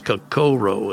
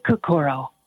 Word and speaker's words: Kokoro: The correct pronunciation is Kokoro. Is that correct? – I say Kokoro.